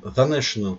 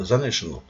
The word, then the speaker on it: The National: И одна из таких команд – это группа National, The National, правильно говорится, как The Beatles, The National.